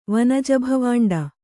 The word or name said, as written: ವನಜಭವಾಂಡ